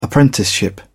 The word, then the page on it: apprenticeship: British English